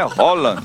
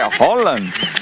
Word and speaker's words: Haaland: Mbappé, Haaland, que não é Haaland, viu? Ele ensinou. Foi ele que ensinou. Ele falou: a pronúncia do meu nome é Haaland. Haaland, Messi, Cristiano Ronaldo. Ele falou que é Haaland.